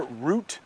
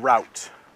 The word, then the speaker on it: route: Excuse me, and you will hear root and route. I say root, but sometimes you will hear Americans say route.